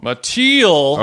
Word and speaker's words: Mattiel: Mattiel.